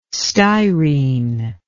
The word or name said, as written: styrene